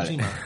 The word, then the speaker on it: Tsushima: Ah, Tsushima.